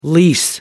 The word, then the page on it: lease: American English